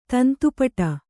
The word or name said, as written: ತಂತುಪಟ